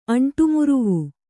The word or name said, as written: ಅಂಟುಮುರುವು